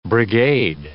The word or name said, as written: brigade